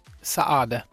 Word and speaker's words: Saade: Saade.